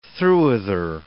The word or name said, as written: throughother